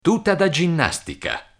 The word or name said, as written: tuta da ginnastica